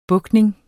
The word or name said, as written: BUGTNINGERNES